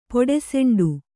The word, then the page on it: ಪೊಡಸೆಂಡು: Kannada